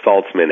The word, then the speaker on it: Saltzman: So my name is spelled M-A-R-C, and the last name is Saltzman, S like Sam, A-L, T like Tom, Z-M-A-N.